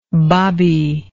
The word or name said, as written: babi